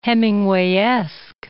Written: Hemingwayesque

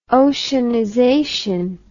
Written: oceanization

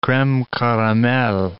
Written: creme caramel

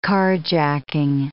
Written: carjacking

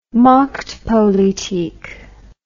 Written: machtpolitik

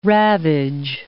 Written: ravage

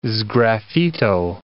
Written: sgraffito

